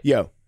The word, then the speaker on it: Yeoh: Yeoh or Yeoh? – I think you're right. – Yeoh. I always want to say yo.